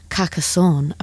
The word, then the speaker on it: Carcassonne: They went to see Franz Ferdinand perform, and I have looked up how to say this, at the Festival de Carcassonne. Apparently, that's how you say it, according to Google, on Monday evening.